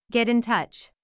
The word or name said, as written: get in touch